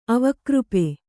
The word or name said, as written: ಅವಕೃಪೆ